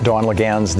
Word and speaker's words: Dawn Laguens: Don, Dawn Laguens, the Executive Vice President of the Planned Parenthood Federation of America. Am I saying your name right, Don? – Yes, you okay.